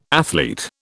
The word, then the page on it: athlete: British English